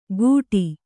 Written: ಘುಟಿ

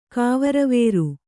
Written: ಕಾವರವೇರು

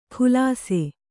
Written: ಖುಲಾಸೆ

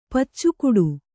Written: ಪಚ್ಚುಗೂಟ

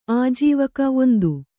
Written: ಆಜೀವಕ

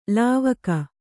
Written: ಲಾವಕ

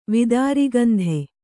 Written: ವಿದಾರಿಗಂಧೆ

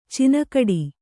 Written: ಚಿನಗಡಿ

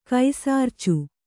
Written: ಕೈಸಾರ್ಚು

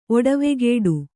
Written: ಒಡವೆಗೇಡು

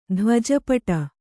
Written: ಧ್ವಜಪಟ

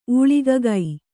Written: ಊಳಿಗಗೈ

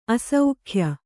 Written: ಆಸೆಗೆಯ್

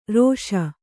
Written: ಋಷಿ

